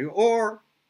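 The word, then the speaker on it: or (אור): With an aleph, it's light, and with an ayin, it's skin, but it's pronounced the same way, or.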